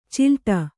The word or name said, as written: ಚಲ್ಲಾಟ